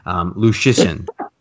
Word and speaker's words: Luszczyszyn: And I think I said his name right. We had to look at YouTube videos before we came on the air to make sure I pronounced it right. Lachishin.